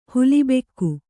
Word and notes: ಹುಲಿಬೆಕ್ಕು: huli bekku; Kannada

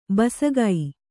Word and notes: ಬಸಗೈ: basagai

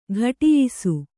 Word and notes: ಘಟಿಯಿಸು: Kannada

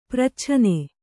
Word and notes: ಪ್ರಚ್ಛನೆ: pracchane

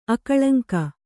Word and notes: ಅಕಳಂಕ: akaḷaŋka

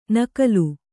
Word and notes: ನಗಲು: kannada

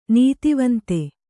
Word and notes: ನೀತಿವಂತೆ: nītivante

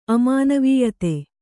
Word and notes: ಅಮಾನವೀಯತೆ: kannada

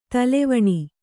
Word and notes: ತಲೆವೆಣ್: kannada

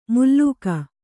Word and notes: ಮುಲ್ಲೂಕ: mullūka

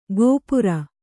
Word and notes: ಗೋಪುರ: gōpura

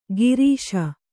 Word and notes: ಗಿರೀಶ: girīśa

